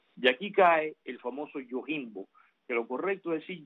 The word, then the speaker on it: Yojimbo: Esto de Yojimbo es realmente en japonés es Yojimbo, es como es, a pesar de que se escribe con J.